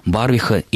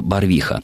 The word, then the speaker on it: Барвиха: во всех случаях ударение падает именно на этот суффикс, поэтому, думаю, и в топонимах Балашиха и Барвиха ударение должно быть точно таким же.